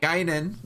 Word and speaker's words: Guinan: How do, Guinan. am I saying that right? Guinan. – Guinan. Guinan. Uh, Whoopi's character.